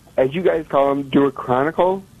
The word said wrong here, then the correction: deuterocanonical: – I think that's how you say it. – Deuterocanonical, yes.